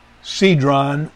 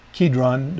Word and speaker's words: Kedron: Uh, he went forth with his disciples over the book Cedron or Kedron, depending on how whether you pronounce the capital C as a as a K sound or an S sound. Um, I've heard it both pronounced both ways.